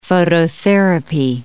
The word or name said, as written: phototherapy